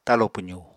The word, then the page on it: talo pwzu: apatani